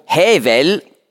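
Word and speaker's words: Hevel (הבל): Abel, hebräisch ausgesprochen, Hevel, das heißt Hauch, Nichtigkeit.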